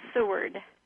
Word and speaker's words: Seward: S-W-O-R-D? – No, S. Seward. – Oh, S-E-W. – Correct, A-R-D. Is it A-R-D or T? – D is in David.